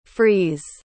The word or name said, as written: frees